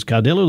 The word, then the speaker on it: Cadillo: Is Cadillo the way you like to say it? – Cadillo is the way we say it, yes.